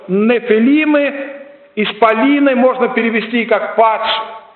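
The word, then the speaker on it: нефилимы (נפילים): По-еврейски слово «исполин» или «богатырь» произносится как «нефилимы». «Нефилимы».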